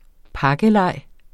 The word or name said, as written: pakkeleg